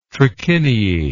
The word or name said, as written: trachiniae